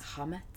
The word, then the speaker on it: chametz: Is it pronounced shametz? – Shametz, that's how I pronounce it. – Oh, okay. – But I don't know if that's correct. It could be Chametz, Shametz, Chametz. – Hametz. – CH is like, that's what's getting me.